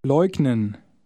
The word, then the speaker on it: leugnen: The G is pronounced as the G in go. Try word 13. – Leugnen.